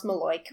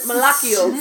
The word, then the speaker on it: malocchio: So it's spelled M-A-L-O-C-C-H-I-O, but it's pronounced Maloik. All which, right. – What? – All right. – Why? Why isn't it Malakio? – So, yeah, no, right?